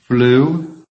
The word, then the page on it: flew: American English